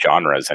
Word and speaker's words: genre: You just can't say genres anymore. – Oh, can't. How do you pronounce it? – It's genre.